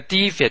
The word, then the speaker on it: defect: 要注意这个 defect 的读音呢。 defect 可以做名词，也可以做动词。做名词的时候，重音要放在第一个音节，读成 defect。